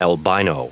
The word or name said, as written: albino